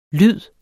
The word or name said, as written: lyd